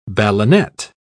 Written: ballonet

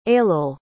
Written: ailill

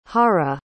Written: hajra

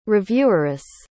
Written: revieweress

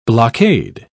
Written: blockade

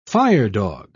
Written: firedog

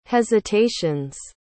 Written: hesitations

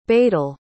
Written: badal

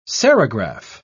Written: serigraph

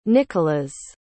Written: Nickolas

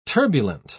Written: turbulent

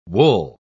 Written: wool

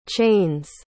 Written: chain's

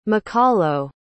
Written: makalo

